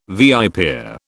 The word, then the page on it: viper: British English